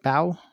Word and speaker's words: Bao: And that continues this year with Bao winning the end. Did I say that right? Bao? – I'm pretty sure you did.